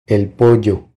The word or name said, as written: El pollo